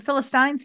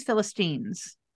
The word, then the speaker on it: Philistines: So after Virgins and Philistines, is it Philistines, Philistines? – I happen to know from being a public radio listener that okay. both are correct pronunciations.